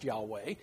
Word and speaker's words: Yahweh: And if you pin them down on the fact that we don't know exactly what the correct pronunciation is, I mean, we can be 99% sure it's pronounced Yahweh.